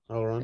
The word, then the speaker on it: Elrond: So there are four: Lord Elrond. How do you say his name? – Yeah, you said it correctly. Yeah, Elrond. Okay.